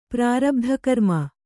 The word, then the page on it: ಪ್ರಾರಬ್ಧಕರ್ಮ: prārabdhakarma